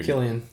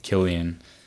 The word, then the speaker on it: Cillian: So, so how do you say his name? You're Cillian. – Cillian.